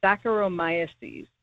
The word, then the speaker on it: Saccharomyces: I'll spell it, but it's called Saccharomyces.